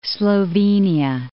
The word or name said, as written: Slovenia